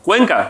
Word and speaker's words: Cuenca: Yeah, is it pronounced right? Cuenca? – Cuenca. – Cuenca. – Yeah, que si se pronuncia así, Cuenca, yes.